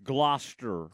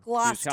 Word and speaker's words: Gloucester: So I think that is pronounced Gloucester. – Gloucester. – Is how you would do that. – Gloucester. – Yeah, from a British standpoint.